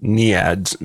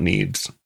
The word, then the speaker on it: NEADS: For the listeners, a small note, I made a pronunciation error here, and it is actually pronounced NEADS, not NEEDS.